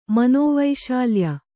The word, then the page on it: ಮನೋವೈಶಾಲ್ಯ: kannada